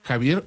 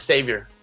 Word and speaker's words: Xavier: Se pronuncia Javier o Xavier, el profesor Xavier.